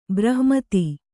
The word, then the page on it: ಬ್ರಹ್ಮೇತಿ: brahmēti; Kannada